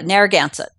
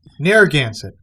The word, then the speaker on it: Narragansett: And you also got your doctorate, your PhD in oceanography from the University of Rhode Island, Narragansett. That's how it's pronounced, right? – Yeah, Narragansett. – Narragansett. Okay.